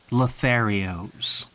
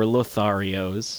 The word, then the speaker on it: Lotharios: This squadron, whatever it is, the Switchblade Lotharios. – Or Lotharios. – Or Lotharios. – We looked it up before the podcast. You can pronounce it either way.